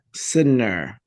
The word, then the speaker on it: Sydnor: It's a hard last name. It's Sydnor. – Sydnor. Very good.